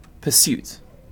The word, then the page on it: pursuit: British English